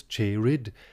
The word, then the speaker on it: cheered: And note that cheered is pronounced here with two syllables, cheered, and even with one syllable, een, in order for the line to scan.